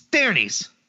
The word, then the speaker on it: Daenerys: We're talking about Daenerys Targaryen? – Yeah, it's Daenerys. – Breaker of Chains, Mother of Dragons? – It's Daenerys. – It's 200% Daenerys. I'm not wrong on this one.